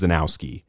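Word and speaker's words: Krzanowski: It's K-R-Z-A-N-O-W-S-K-I. So I'm going to assume it's Zanowski. – It sounds like you said like three different radio stations. – It's K-R-Z-N-O. I believe it's going to be pronounced Zanowski. – Okay. – The K and the R, I've got to assume like a Krzyzewski. They're silent and they're just there to throw us off.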